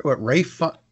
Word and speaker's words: Ralph: It's got some great, I mean, Rafe, why is it called pronounced Rafe when it's spelled Ralph? – Because anyway, they're funny. Because they're funny out there, because they don't like to say Ralph.